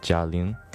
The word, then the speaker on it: Jia Ling: Uh, I do know that this movie was written, directed, and produced by um, a leading female comedian in China named Jia Ling.